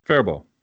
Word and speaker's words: Faribault: Faribault. – Faribault. – Yeah. – Everyone calls it Faribault. But that's a French word, though. – Faribault. – The B-A-U-L-T, that's a French thing.